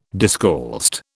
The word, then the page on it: discalced: British English